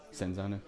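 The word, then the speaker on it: Cinzano: I keep calling it Serenzano. Senzano. – Senzano. – Senzano. I want to go ahead and publicly apologize for my mispronunciation of the name. It's been Senzano the entire time, not Serenzano.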